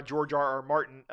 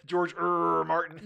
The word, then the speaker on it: George R. R. Martin: I think it's pretty clear that I agree with you on George R. R. Martin. – You said it wrong. – George R. R. Martin. – That's right.